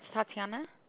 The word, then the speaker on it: Tatiana: It's Tatiana. – Can you spell that? – T-A-T-I-A-N-A.